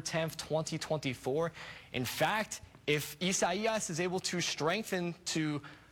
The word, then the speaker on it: Isaias: And yes, a lot of people have been kind of butchering that pronunciation. That is how you say Isaias.